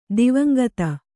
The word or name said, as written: ದಿವಂಗತ